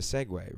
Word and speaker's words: Segway: A Segway is a Segway, right? Same word? Am I doing a Chris J thing where I'm pronouncing it wrong? – No, that's correct.